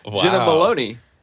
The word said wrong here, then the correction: Malone: Jenna Malone. Like, how do you say her last name? – Malone. – Jenna Malone, not yeah. Maloney.